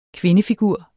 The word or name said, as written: kvindefigur